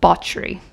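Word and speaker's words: Báthory: It looks like Bathory, but it is pronounced Bauchery.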